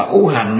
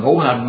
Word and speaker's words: ngũ hành: Bà chú sư, bà ủ hành á, ngũ hành mà nó đọc là ủ hành, mấy thằng mà nó học chữ, nó học là cò mà nó không học chữ nho đó, chứ ngũ năm của người ta mà ngũ hành ta mà nó đọc là ủ hành đó ha.